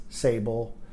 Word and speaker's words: Sabel: Sable, and it's spelled S-A-B-E-L, @grantspassoregon.gov.